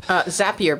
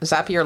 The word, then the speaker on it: Zapier: Zapier, because it rhymes with happier, is what is. I think that's what our friend Tim Stringer told us is the correct. Zapier, like happier.